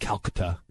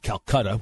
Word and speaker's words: Calcutta: which I learned is pronounced Calcutta, not Calcutta, which is the way I always thought it was pronounced.